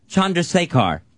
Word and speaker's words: Chandrasekhar: Chandrasekhar is how you say Jay's last name.